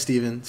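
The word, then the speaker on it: Stephan: Oh wait, I don't know what it is, but regardless, my name's Steven, spelled with a PH and an A. So it's like Stefan, but you say it's Steven.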